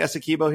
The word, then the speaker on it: Essequibo: Did I say that right? I know you know how to pronounce it. But Essequibo, thank you. Essequibo. I got it right earlier and I got it wrong this time.